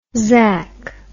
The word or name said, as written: zack